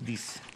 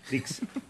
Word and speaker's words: disc: Del Compact Disc. – Disc. – Dix. En Paraguay. – No, espera. Dix no es. D-I-S-C. Claro, disc. – Claro, de disco.